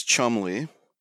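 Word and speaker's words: Cholmondeley: So this guy, his name is Chumley. It's pronounced it's Charles Chumley. And this is a very English name. I wanted to just stop and point this out. Because did you see the way this guy's name is spelled? – It didn't stand out.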